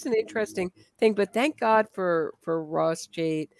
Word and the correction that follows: Cheit: I hope I'm pronouncing his name right. – Oh, it's Chite, not. – By the way. I only know that thank because you. In interview with Dr. Loftus, I was saying Chate, and she's like Chite, which is one of the second most embarrassing interview moments in my life.